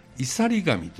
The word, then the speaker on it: 岩座神: 岩座神と書いて、いさり神というもんです